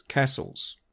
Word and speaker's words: Cassilis: It's read Cassilis, but it apparently pronounced Cassils.